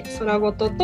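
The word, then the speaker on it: 宙言: と宇宙の宙に言葉のことと書いて空ごとと読みます。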